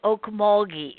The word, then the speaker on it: Okmulgee: It's Okmulgee. It is hard G. You're absolutely right. – I wasn't sure. Okmulgee.